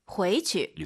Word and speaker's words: 回去: 回去. – 이렇게 발음하시기 바랍니다. 回去 해도 되지만 경성으로 回去.